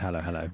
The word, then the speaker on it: Halo Halo: Yeah, and your studio is, it's Halo Halo. – Halo Halo, yeah.